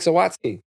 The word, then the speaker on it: Zawadzki: we have uh, Mr. Mike Zawadzki. Did I say it right? – Yes, actually, it's perfect.